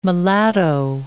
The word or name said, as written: mulatto